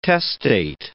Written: testate

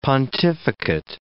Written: pontificate

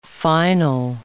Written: final

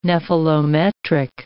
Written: nephelometric

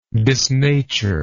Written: disnatured

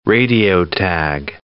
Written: radio-tag

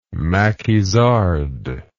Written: maquisard

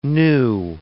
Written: nu

